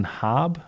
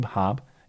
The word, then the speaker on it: Haab: There was some additional testimony from Colton Hobb, I think is how you pronounce it. Colton Habe, Hobb, H-A-A-B.